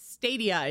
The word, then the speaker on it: Stadia: Yeah, I think it's Stadia. It's like Stadium, but yeah.